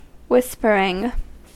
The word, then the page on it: whispering: American English